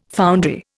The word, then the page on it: foundry: Indian